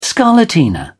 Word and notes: scarlatina: British English

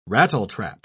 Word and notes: rattletrap: British English

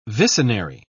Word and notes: vicenary: British English

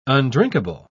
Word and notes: undrinkable: British English

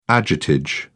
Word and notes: agiotage: British English

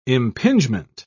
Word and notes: impingement: British English

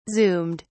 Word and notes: zoomed: British English

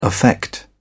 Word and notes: affect: British English

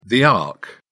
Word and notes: ark: British English

